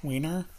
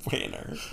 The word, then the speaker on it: Wanner: Wiener? – Wanner. Nearly a month after the challenge was posted. – Can you spell that? – W-A-N-N-E-R. – Okay. – Not even close to Wiener. Not Wiener, guys.